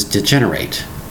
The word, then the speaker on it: degenerate: Spelling is D-E-G-E-N-E-R-A-T-E. Now, at first glance, you might say, let's pronounce this degenerate, but it's pronounced degenerate. The last part is like I-T or it.